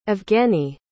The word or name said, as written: evgenii